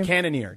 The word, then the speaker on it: Cannonier: Cannonier.